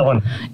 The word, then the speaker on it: Illinois: And first of all, I got to ask, do you pronounce it Illinois or Illinois? This is a dumb Southerner question. – Illinois.